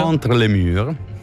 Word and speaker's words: Entre les murs: Entre les murs.